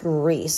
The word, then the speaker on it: Rhys: And it's pronounced Reese.